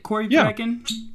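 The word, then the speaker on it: Corryvreckan: Is it Corey Vrekken? – Yeah, Corey Vrekken.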